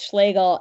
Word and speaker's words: Schlegel: People look at Schlegel and get very nervous and ask if they can just kind of... There's a lot of trying to dodge it. And then I tell them it rhymes with bagel and everyone is put at ease.